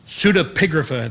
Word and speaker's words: pseudepigrapha: But there were also what is called pseudepigrapha, I think is the best way to pronounce that.